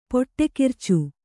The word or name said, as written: ಪುಡಿಖರ್ಚು